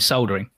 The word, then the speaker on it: soldering: You've got a list of people who you know are interested in soldering. – Soldering? – Yeah, there's an L in it. So it's soldering. – Soldering. – Yeah. – Wow. – It is spelled S-O-L-D.